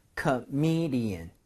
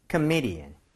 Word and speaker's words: comedian: 근데 강세를 예, M-E 쪽에다가 줘야만 합니다. 그래서 comedian 이런 식으로 발음을 해볼 거예요. 두 번 갈게요. comedian, comedian, comedian, comedian.